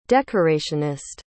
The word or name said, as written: decorationist